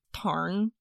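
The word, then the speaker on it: Tairn: I watched a girl on TikTok who speaks Scottish Gaelic, and she was saying it's pronounced like Tarn. In her Gaelic lilt.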